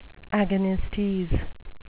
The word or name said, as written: agonistes